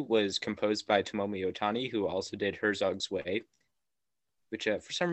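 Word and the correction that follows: Herzog Zwei: Actually, because it's German, I just wanted to say I think it might be pronounced Herzog's Vi.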